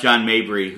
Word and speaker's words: Mabry: My dad did pronounce John Mabry, who was his favorite player for a long time in the 90s, John Mayberry. For a long, long time. Yeah.